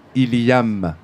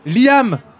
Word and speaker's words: Liam: Liam. – Non, excusez-moi. Je prononce mal son prénom. Ilham. – Liam. – Oui. Liam, L-I-A-M.